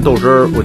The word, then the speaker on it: 豆汁儿: 不能接受的就是那个豆汁儿，实在是咽不下去，但是营养价值特别高吧。 – 可能大多数人接受不了它那个味道吧。 – 我吃不惯这个什么，我吃不惯豆汁儿，我觉得不好喝。 – 豆汁，一般的老北京呢会把它念成豆汁儿，是一款北京独具特色的传统小吃，以绿豆为原料进行发酵产生的，具有养胃、解毒、清火的功效。